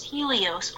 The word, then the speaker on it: Helios: Helios, right? Right? Maybe? Pretty sure. – Yes, Helios.